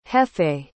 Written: jeffe

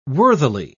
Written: worthily

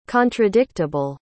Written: contradictable

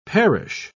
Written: parish

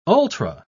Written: ultra

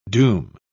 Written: doom

